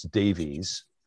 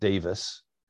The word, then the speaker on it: Davis: And we would look at his name and we would think it's Davies, but he's Welsh. They pronounce it Davis.